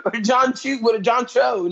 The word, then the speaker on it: John Cho: John Cho. No, I said it right. This time.